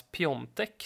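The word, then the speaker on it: Piątek: Sen som för uttalandets skull här, hur tycker du egentligen om det här med att Piatek ska uttalas Piontek?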